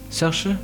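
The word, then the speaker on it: Saoirse: Sersha Sersha.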